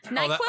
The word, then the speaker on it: Noqual: How do you guys pronounce 22. – This? – 22. – N-O-Q-U-A-L. Noqual? – Diaspora. – Noqual? – Oh, yeah. Yo, man, that's Noqual.